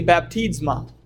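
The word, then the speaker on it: baptizma (βάπτισμα): The Greek word is actually baptizma. If you were to pronounce it, it sounds just like our word for baptism.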